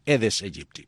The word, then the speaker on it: Aedes aegypti: Porque esta mañana estuvimos conversando sobre la pronunciación del mosquito. Y Bruno, pues, dice que se dice así: Aedes aegypti.